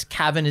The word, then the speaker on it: Kavon: Okay, it's called The Cavern. Cool. You can look it up. It's Cavern is spelled K-A-V-O-N.